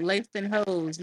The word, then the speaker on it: Langston Hughes: His name can't be Langston Hughes, man. Oh, you going for that? – You said it right the first time. Langston Hughes, not Hughes.